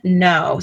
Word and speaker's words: Knowe: The Children of Green, no. So no is spelled K-N-O-W-E.